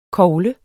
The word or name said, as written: kogle